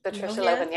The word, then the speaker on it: Patricia Lohan: She, Patricia Lohan, is that how you say it, Lohan? – Patricia Lohan, yep.